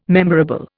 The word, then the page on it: memorable: Indian